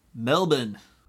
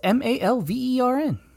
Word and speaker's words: Malvern: Before Australians yell at us, I believe it's pronounced Melbourne. – No, it's Malvern. – Oh, I thought you were just mispronouncing Melbourne.